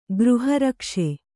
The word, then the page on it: ಗೃಹರಕ್ಷೆ: Kannada